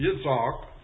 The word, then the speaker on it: Yitzhak: That word Isaac, it's in the Chaldee Hebrew, it's Yitzhak, and we've corrupted that into Isaac, close as our English tongues can pronounce it.